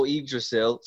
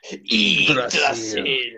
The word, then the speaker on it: Yggdrasil: There's the whole Yggdrasil. Sorry, I said that wrong. – Yggdrasil.